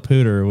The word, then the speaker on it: Poudre: They call it Poudre, which I mispronounced it originally.